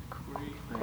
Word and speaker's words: quae: How do you pronounce Q-U-A-E? – Quay.